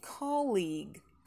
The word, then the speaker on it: colleague: Действительно, у нас там есть и долгий, но не ударный. Поэтому основное ударение все-таки на первый. И чуть-чуть потянули, чуть-чуть пошире, чтобы получился долгий. Colleague, colleague.